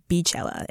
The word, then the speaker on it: Beychella: As DJ Khaled said, pronounced during the Coachella performance, it's Beychella.